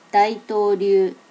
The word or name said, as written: daitoryu